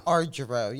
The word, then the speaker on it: Argiro: Argiro, yes.